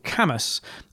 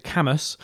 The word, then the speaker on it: Cambois: which is actually a place called Camas. So Camas is a little bit of an unusual word, Cambois, C-A-M-B-O-I-S, but you pronounce it Camas, in Northumberland.